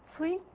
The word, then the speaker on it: Cui: And I want to present the work of Cui. You have to pronounce it T-S-U-I, who has set up the whole program to extend certain theorem to this setting.